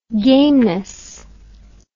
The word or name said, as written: gameness